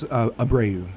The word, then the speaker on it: Abreu: Is that how it's pronounced? – It's Abreu. – Abreu. Oh, thank you. – It would be Abreu if it was in French.